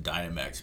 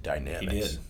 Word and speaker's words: Dynamex: I like to pronounce it Dynamex, but there is some debate as to what is the correct pronunciation. I think we've had that debate. – The lawyer that was on that talked about it called it Dynamex.